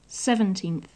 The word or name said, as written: seventeenth